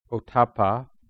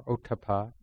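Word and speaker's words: ottappa: So in the tradition, many of you know, but we'll be studying these particular teachings on Hiri Otapa, or Otapa, depending on how people pronounce it.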